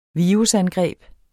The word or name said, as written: virusangreb